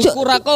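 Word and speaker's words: Curaçao: Ya Jungle Queen ini adalah perpaduan blue, apa ya bacanya? Curak. – Blue Curaco. – Curaco.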